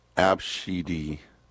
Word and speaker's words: Abcde: It's spelled A, B, C, D, E. They say it's pronounced Abshidi. Abshidi? – Oh.